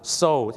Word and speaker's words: sew: SEW ออกเสียงว่าโซครับ so ถ้า